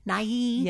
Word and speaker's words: Nighy: Yeah, Nighy. Bill Nighy. – Nighy.